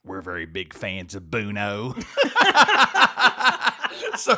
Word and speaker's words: Bono: people could be confused and they might not realize Bono is pronounced Bono. They might say Bono if they saw it, like Sonny Bono.